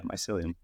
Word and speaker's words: mycelium: Am I saying that right? – Yeah, mycelium.